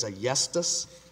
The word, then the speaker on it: Ayestas: I believe it's Ayestas, A-Y-E-S-T-A-S.